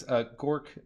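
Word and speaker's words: Görq: For our audience, Gork, according to Peter, is spelled G O with an, I believe that's an umlaut above it. – Yep. Yep. – R Q. Gork.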